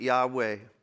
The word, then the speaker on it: Yahweh: It's actually pronounced YHW, or spelled YHWH, but for English reasons, so that we could pronounce it as they would have understood it then, there's been a couple vowels added, an A between the Y and the H, and an E between the W and H, so that we can pronounce it Yahweh.